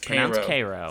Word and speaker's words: Cairo: Cairo? I got to get that right. – It's pronounced Cairo.